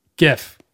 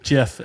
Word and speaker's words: GIF: Graphics is a hard G, so it should be GIF.